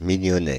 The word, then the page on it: mignonnet: France (Île-de-France); [mi.ɲɔ.nɛt]